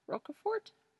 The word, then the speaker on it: Roquefort: Roquefort? – Yep.